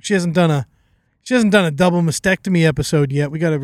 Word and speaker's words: mastectomy: Double mastectomy.